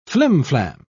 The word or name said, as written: flimflam